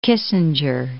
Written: Kissinger

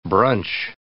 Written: brunch

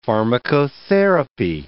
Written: pharmacotherapy

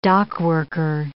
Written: dockworker

